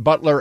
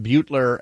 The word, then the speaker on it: Butler: Just a heads up, the pronunciation of our third congressional district representative, Jamie Herrera Butler, her pronounced Butler as in the who brings hors d'oeuvres, not Butler as in Ferris Bueller, John from Centralia.